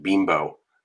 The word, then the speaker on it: Bimbo: Yeah, if they change, I will miss having to explain to people that it's pronounced bimbo, not bimbo bread.